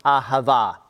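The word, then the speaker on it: ahava (אהבה): And this is pronounced ahava.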